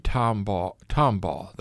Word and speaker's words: Tombaugh: Yeah, Clyde Tombaugh, that's the correct pronunciation.